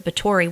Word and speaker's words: Bathory: So you can literally look up Countess Elizabeth of Bathory, which it's B-A-T-H-O-R-Y, but the T-H is spelled with a or sounded out with a T.